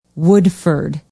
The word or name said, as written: woodford